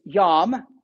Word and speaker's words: yam: The Hebrew word for the sea is yam, you know, kind of like a yam, spelled like a yam, yam.